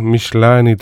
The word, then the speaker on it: Michelini: Michelini restoran vist peaks olema õige hääldus.